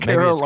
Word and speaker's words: Carolans: Oh, Carol Ann's. – Yeah, it's spelled Carolan. Carolans. – Carolans.